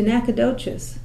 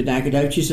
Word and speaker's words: Nacogdoches: What led you to move to Nacogdoches? See, I said it correctly. – Well, what led me to move to Nacogdoches is I was born here.